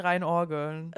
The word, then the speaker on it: reinorgeln: Und also, dass dann halt eher ein Joint geraucht wird, als halt, dass sich einer reingeörgelt wird, wie du gesagt hast. – Ich habe es tatsächlich falsch gesagt, das heißt eigentlich reinorgeln. – Reingeorgelt.